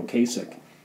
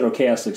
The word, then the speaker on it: Ocasek: I would, yeah, I guess I would ask Rick Ocasek, who, by the way, I spent 30 years pronouncing his name Ocasek, and one of these interviews he introduced himself and he said Ocasek, so I'm going to go with that. I figure he might know.